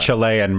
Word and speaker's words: Cillian: It's pronounced with a hard C. It's Cillian Murphy. Didn't know that. – Chilean Murphy.